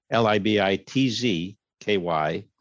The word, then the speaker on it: Libitzky: And Libitzky is spelled L I B I T Z K Y, no S.